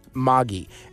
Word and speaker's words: Magee: It's pronounced Moggy, M-A-G-E-E.